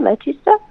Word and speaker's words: Leicester: Under and, a yeah, car and I think it, how you pronounce it like Leicester, Leicester. Right.